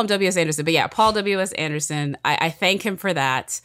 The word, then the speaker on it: Paul W.S. Anderson: Paul W.S. Anderson.